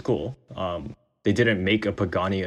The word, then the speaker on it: Pagani: So that's how you pronounce the brand. Oh, geez. I've been butchering it. – Pagani.